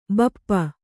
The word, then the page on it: ಭೂಪ: Kannada